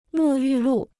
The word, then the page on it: 沐浴露: mù yù lù